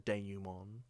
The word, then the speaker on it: denouement: you know, said denouement.